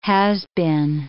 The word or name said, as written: has-been